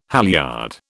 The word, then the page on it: halyard: British English